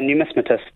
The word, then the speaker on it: numismatist: A numismatist? Yeah, numismatist.